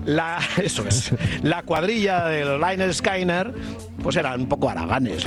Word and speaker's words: Lynyrd Skynyrd: Ya el álbum de aquel disco se titulaba Pronunciate Lynyrd Skynyrd. Te está diciendo cómo nos llamamos. El disco se titulaba Pronunciate Lynyrd Skynyrd. Somos los Lynyrd Skynyrd. ¿Por qué somos los Lynyrd Skynyrd? Mira, existía un profesor de gimnasia que se llamaba Leonard Skinner.